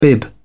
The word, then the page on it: bib: British English